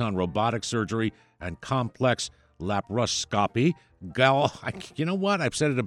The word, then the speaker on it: laparoscopy: Dr. Bogardis specializes in general surgery with a focus on robotic surgery and complex laparoscopy. Laparoscopy is the actual term. I added an extra syllable.